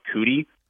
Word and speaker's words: Kutty: My name is Mark Cootie. It's just like you guys have like Brendan Cootie, but okay. It's spelled K-U-T-T-Y. – K-U-T-T-Y.